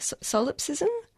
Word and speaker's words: solipsism: And you also um, mentioned white, I don't know if I'm going to pronounce this correctly, solipsism? – Yeah, that's right.